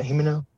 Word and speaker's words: Himeno: He basically, like, you see him, whenever you see him lose Himeno, is that how you pronounce it? Himeno?